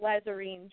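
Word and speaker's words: Lhazareen: But I do pronounce it Lazarine, just like you did, the sheep people.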